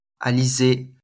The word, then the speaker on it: Alizée: J'ai le plaisir de recevoir Alizé Manker, je ne sais pas si je prononce bien.